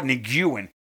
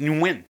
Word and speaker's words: Nguyen: But they're like, oh, Nguyen. No, and it's pronounced Nguyen.